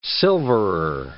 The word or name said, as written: silverer